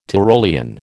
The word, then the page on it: Tyrolean: American English